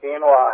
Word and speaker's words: quinoa: Quinoa. – Pardon me? – It's called quinoa.